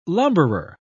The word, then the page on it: lumberer: British English